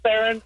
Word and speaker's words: Theron: Theron. – Theron like Charlize. – Exactly. Hmm. And pronounced like Theron.